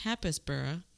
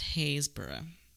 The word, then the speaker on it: Happisburgh: that I pronounced Happisburgh, because that's how it's spelled, but apparently it is pronounced Haysburgh, which, you know, of course, silly me. Why put a P in, is what I'm saying. Why put the P's in if you're not going to say the P's?